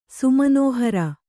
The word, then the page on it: ಸುಮನೋಹರ: sumanōhara